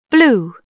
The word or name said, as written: blue